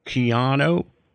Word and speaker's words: Keanu: I got to say, uh, I, I, I, think uh, Kino is is a is an, is a great actor. Is it Kino or Keanu? He said people call him Keanu. It's actually pronounced Keanu.